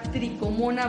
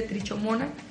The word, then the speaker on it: tricomona: Que es un parásito que se llama tricomona vaginalis se escribe trichomona. – Es también correcto decirle trichomona. A nosotros nos gusta más decirle trichomona.